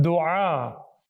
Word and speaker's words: dua: Yes, it's actually technically, you know, the actual correct Arabic pronunciation, dua.